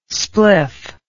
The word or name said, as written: spliff